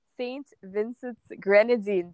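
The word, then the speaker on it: St. Vincent and the Grenadines: So I was from Canada and she was from St. Vincent's Grenadine.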